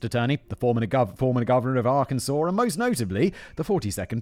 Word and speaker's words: Arkansas: I once pronounced Arkansas, where Bill Clinton's from, as Arkansas, and everyone was like, ah, Arkansas?